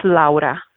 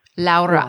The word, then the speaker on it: Laura: I had to ask because it's uh, your name is spelled like Laura, and but you pronounce it. You will you pronounce it for everyone because it's so beautiful how you say it. Sure, it's Laura. Laura.